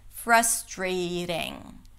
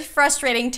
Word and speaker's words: frustrating: You can say frustrating, that's also okay, but you can make it a little smoother and softer and more American by making it a flap T. Frustrating. It's very frustrating to know what you want to say, but not being able to say it.